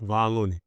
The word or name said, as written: kevon LOONEY